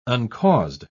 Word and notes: uncaused: British English

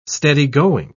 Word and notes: steady-going: British English

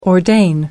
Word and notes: ordain: American English

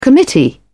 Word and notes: committee: British English

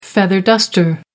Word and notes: feather duster: American English

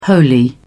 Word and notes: holy: British English

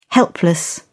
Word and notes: helpless: British English

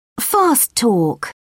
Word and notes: fast talk: British English

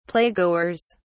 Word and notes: playgoers: British English

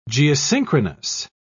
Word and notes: geosynchronous: British English

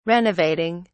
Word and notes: renovating: British English

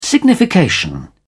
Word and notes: signification: British English

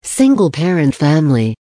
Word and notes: single parent family: American English

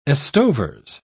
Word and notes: estovers: British English

